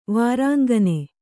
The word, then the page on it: ವಾರಾಂಗನೆ: kannada